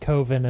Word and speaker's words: Coven: The actor is very much of the position that it should be pronounced Coven because that's how you pronounce that word. Mark says, well, Coven sounds like oven.